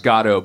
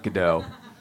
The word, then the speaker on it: Godot: It's pronounced Godot, by the way, not Godot.